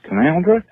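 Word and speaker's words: Canowindra: Canawindra is pronounced Canawindra, and that other one in Western Australia that I can't even pronounce the way you pronounced it is Kununurra.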